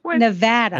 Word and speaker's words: Nevada: I love it when I get the inside scoop on how to really pronounce something like Missouri and Cincinnati. It's Nevada and Louisville.